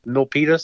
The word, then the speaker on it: Milpitas: I drove all the way down to tell me if I'm pronouncing this right, Miltipas, Milpitas, something Milpitas. like that.